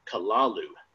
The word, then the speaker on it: Kalalu: May be taken as something like Kalalu, with the first syllable pronounced gutturally and very thickly. The U is about like that in full, with the first syllable not unlike Kalu in the sound, hence the H represents guttural thickness.